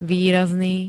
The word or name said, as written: výrazný